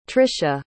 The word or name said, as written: trisha